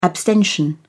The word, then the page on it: abstention: British English